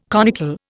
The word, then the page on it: conical: Indian